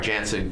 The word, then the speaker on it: Jansen: We're going to give him the opportunity to close, making Jensen probably the most dominant eighth inning guy in Major League Baseball. And really, Jensen has closer stuff. I'm sorry, Jansen, Kenley Jansen. Let's get the name right.